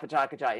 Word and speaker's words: Pecha Kucha: I finally got Patakacha in my head, as that's how to pronounce it, because it is spelled. Go ahead. – P-E-C-H-A-K-U-C-H-A.